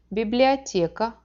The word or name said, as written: biblioteka